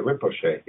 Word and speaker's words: Rinpoche: You talk about the guy, and I'm going to butcher his pronunciation, and I ask for your correction, is the Rinpoche? Is that how you pronounce it? – Oh, that's pretty darn good. The Rinpoche, yes.